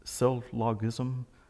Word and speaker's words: syllogism: And that is called syllogism. Did I say it right? Syllogism? S-Y-L-L-O-G-I-S-M. Syllogism. Which really what that means is it is theology of divine retribution.